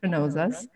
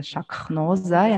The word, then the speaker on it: Shanoza: Well, people actually were struggling to pronounce my name, and of course, obviously, Shaknoza, and also like when you write it, Shaknoza, it's hard.